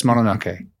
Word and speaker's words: Mononoke: I tried to say that correctly. Did I get away with it? – You did. It was okay, because I was about to say Mononoke like I would normally, but then I thought James is always correcting me.